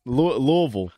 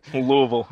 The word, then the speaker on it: Louisville: But Louisville. Louisville. You get it as close to one syllable as possible.